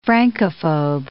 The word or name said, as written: Francophobe